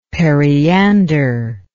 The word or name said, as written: periander